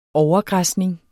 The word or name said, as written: overgræsning